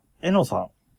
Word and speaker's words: ENO: えー、で、次のメールが、えのさん。えーはい ENO と書いてえのさんですね。